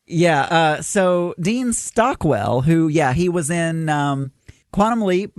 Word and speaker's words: Stockwell: That's the Dean Stockton tie in there from Battlestar. – It's actually, I'm giving it to you, but you are pronouncing it incorrect. It's Dean Stockwell.